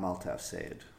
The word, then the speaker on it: Altaaf Sayed: But in order to get my name right, just remember that it's not what you say, it's how you say it. And I'm Altaaf Said.